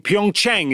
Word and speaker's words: Pyeongchang: South Korea in Pyeongchang. Is that correct? Have I pronounced that name as well? – That is correct.